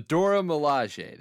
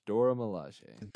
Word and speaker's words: Dora Milaje: Delora Mazengale, real estate agent. The Dora Milaje. There we go. I was putting the L in the wrong word. Dora Milaje.